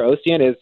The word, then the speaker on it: Ocient: You wrote about a company I don't think I can pronounce, OCIENT, Ocient? – Yeah, Ocient.